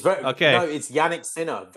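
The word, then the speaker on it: Jannik Sinner: How do you pronounce his name? Is it Yannick Sinner or Sinner? – That's it. It's Yannick Sinner.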